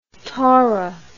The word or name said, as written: tora